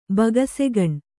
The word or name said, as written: ಬೊಗಸೆಗಣ್